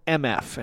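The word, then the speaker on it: Emefe: But this band's called MF, and it's spelled E-M-E-F-E. I think they're based in Brooklyn. The name is, MF, to represent what you think might start with those two letters, right? Yeah, that's the feeling you get when you listen to MF. You almost want to say MFA when you see it written, but that's not how you say it.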